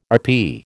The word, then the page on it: RP: American English